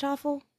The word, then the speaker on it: offal: Where it's like, okay, like, they'll have, like, awful or, is it pronounced awful? – I think O-F-F-A-L. Yeah.